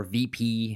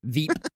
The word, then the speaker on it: Veep: Veep, or VP, however you want to pronounce that. – It's Veep. – Veep. – It's pronounced Veep.